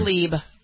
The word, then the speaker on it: Tlaib: One of the other, I don't know how to, is it Tlaib? How do you pronounce that lady's name? – Tlaib.